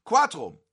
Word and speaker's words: Cuatro: Mehrere Personen sagen Cuatro. Cuatro, ich weiß, ich habe es nicht richtig gesagt.